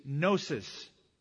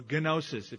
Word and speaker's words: gnosis: And the word for knowledge in Greek is gnosis, or gnosis, if you wanted to mispronounce it. G-N-O-S-I-S, gnosis.